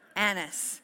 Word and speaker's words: Annas: Now you got to say that carefully. It is a short A. Annas, okay? If you're wondering what taxes have to do with Jesus, we're about to find out.